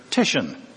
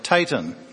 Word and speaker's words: Titian: I wanted to learn how to pronounce that 16th century Italian painter Titian and not call him something else, something wrong, like Titan or something.